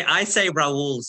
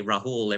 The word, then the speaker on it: Rahul: I say Rahul since I've been living in the US because every time Rahul. I say Rahul, everyone says Rahul. So, I've changed it to Rahul, but it is Rahul.